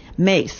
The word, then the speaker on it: Myss: Okay, C-A-R-O-L-I-N-E. – And Mace is M-Y-S-S. – Okay, Mace. So it's a different spelling than you would think.